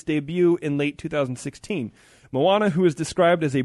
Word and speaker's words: Moana: Speaking of heroines in movies, as Frozen was a giant success, like $1 billion, Disney has just introduced a new heroine called Moana, I believe is how you pronounce it.